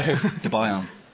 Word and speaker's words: Debian: Everyone just pronounces Debian in a different way. – Debian. – Debian. – It's Debian. Debian. Debian. – But it's Debian. – I know. – It is. – From Deb and Ian.